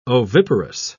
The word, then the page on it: oviparous: British English